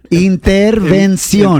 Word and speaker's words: intervención: Yeah, it's the N. Just get the N, Prima Vani. Intervención.